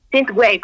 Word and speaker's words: Synthwave: Synthwave. – Synthwave, ajá.